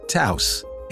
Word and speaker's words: Taos: Unfortunately, you're all saying it wrong. It's not the Taos or Taos or Taos, and if you think it's the Toes, Toss, or Toast, then come on, you're not even trying. It's Volkswagen's all-new, perfectly-sized compact SUV, Taos. And it rhymes with house.